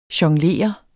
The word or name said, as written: jonglere